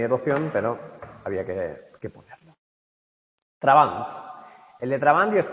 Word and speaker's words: Trabant: Dice, más coherente, de entre los supuestos teóricos, es el de Jürgen Trabant, o Trabant, como se pronuncia.